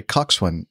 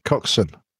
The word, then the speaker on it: coxswain: I want to say coxswain, but it's how would you pronounce? – Coxswain. – Coxswain. Thank you.